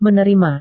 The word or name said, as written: menerima